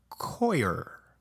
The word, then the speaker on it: coir: They're grown hydroponically in coconut coir. Yeah, that's right. It took me forever to figure out how to pronounce that. C-O-I-R. Coir.